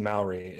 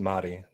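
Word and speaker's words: Māori: By the way, Rugal, it's not pronounced Maori. It's Mahdi. That's how it's pronounced.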